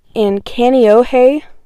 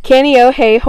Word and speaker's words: Kaneohe: So, in Kaneohe, which I know that's the pronunciation because I looked it up. Kaneohe, Hawaii, will be the home of Chris Brown for the next 4 years.